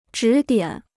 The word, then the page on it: 指点: zhǐ diǎn